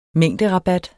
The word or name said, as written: mængderabat